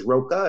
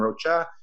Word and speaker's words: Rocha: Like there's Rocha and Rocha. I'm Mexican and there's different pronunciations and different spellings of my last name as well, right? So there's different ways to say it.